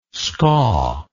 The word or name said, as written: skaw